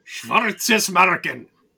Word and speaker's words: Schwarzenmarken: And then we also got a submission off of Twitter, a short one with Erased, 91 Days, and Schwarzenmarken. I don't believe I'm pronouncing that correctly, but... – Schwarzenmarken! – Yeah, okay, we'll go with yours. – It's Germanish, so you have to yell.